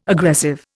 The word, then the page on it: aggressive: Indian